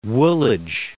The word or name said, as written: Woolwich